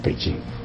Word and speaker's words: 北京: 就是来问我“ 北京” 两个字到底怎么准确的发音法，那么我就把北京拼音的这个文字，这是 J I N G J 之前再加上一个 D，那么加上一个 D 以后啊，再也不可能像西班牙文那样那个 J 念成呵的声音，不会念成北京，那只能北念北京。